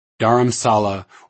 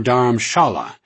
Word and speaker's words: Dharamshala: Dharamsala, or Dharamshala, as it is pronounced in Hindi,